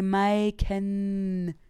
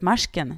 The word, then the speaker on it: Maiken: Och det blir ju som att alla tror att hon heter Michael. Alltså... Men nej, Maiken. Och då tror alla att det ska uttalas Marsken när de läser. Ah. När de läser liksom med J, Marsken.